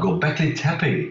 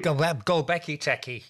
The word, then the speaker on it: Göbekli Tepe: I always get the name wrong, Göbekli Tepe in Turkey. – Ah, Göbekli Tepe. – Göbekli Tepe, yes.